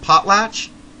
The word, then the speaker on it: Potlatch: And this album is called Potlatch? – Yeah.